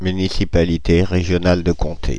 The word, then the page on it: municipalité régionale de comté: /my.ni.si.pa.li.te ʁe.ʒjɔ.nal də kɔ̃.te/